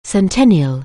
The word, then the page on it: centennial: anglais